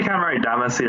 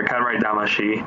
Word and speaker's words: Katamari Damacy: It's a game series called Katamari Damacy, right? I pronounced that right? – Yeah, you can say Katamari Damacy or Katamari Damashi. It doesn't really matter.